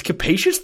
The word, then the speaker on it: capacious: Let me see how to say this. Capacious. That is exactly how you pronounce it, by the way.